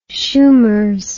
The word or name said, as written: shoomers